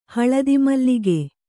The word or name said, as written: ಹಳದಿಮಲ್ಲಿಗೆ